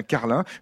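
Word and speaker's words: Carling: c'est comme ça qu'on prononce, hein, ça s'écrit ING à la fin, mais on dit bien Carlin, une rue de Carlin existe à Iteuil, ici dans la Vienne, dans cette commune résidentielle et rurale.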